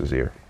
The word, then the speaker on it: Shazier: I'm pretty sure it's Shazier. It's not even that hard.